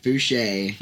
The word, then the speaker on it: Fooshee: So it's pronounced Fooshee. – Fooshee, yes, sir.